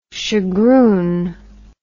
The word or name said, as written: shagroon